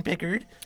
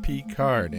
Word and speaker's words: Picard: Take it away, Captain Pickard. – That's pronounced Picard, Ensign Silly. I am French.